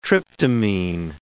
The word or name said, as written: tryptamine